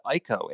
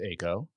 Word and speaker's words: Ayco: First of all, for those who may not know, can you explain what ICO, A-Y-C-O, is? – Sure, I'd be happy to. So first off, it's pronounced ACO. But beyond that, a lot of people get that wrong.